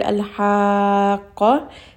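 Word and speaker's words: Al-haqqa (الحاقة): Another example, we have the word al-haqqa in Surah Al-Haqqa, the first ayah. So I'm going to give it a little bit of emphasis on the qaf. Al-haqqa. So make sure that you know that there is a shadda on this letter after the med.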